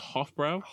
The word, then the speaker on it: Hofbräu: It's Hofbräu.